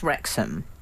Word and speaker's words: Wrexham: So, Rob McElhaney and Ryan Reynolds, already, they bought Wrexham.